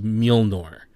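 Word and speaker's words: Mjölnir: It's Thor's hammer, and that's what I'm going to call it, because I couldn't even pronounce it, but it is Mjolnir.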